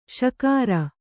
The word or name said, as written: ಷಕಾರ